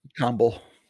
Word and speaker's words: Tomball: Is that how you say it? Tomball? – Tomball. – Yeah. Tomball. Even though it's spelled with a B-A-L-L? – Yeah, probably not Tom Ball. Yeah, that's probably not a good way. Yeah. Hi, I'm here to see Tom Ball.